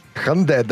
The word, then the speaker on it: Hyundai: Hyundai. That's how you say it.